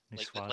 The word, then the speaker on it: Niçoise: Niçoise. Like the salad.